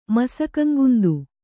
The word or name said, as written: ಮಸಕಂಗುಂದು